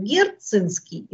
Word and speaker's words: герцинский: Дело в том, что есть два слова, которые пишутся по-латински одинаково, только в одном случае – он читает, это слово читается как герцинский или герцания, а в другом как геркани.